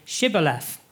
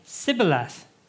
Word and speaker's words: Shibboleth: If the man said, "No, I am not," they would tell him to say, "Shibboleth." If he was from Ephraim, he would say, "Sibboleth," because the people of Ephraim cannot pronounce that word correctly.